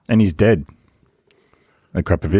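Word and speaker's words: Akrapovič: That's how it's pronounced. It Akrapovic, right? – Everyone says Akrapovic. – It's not. It's Akrapovic, right? – Akrapovic.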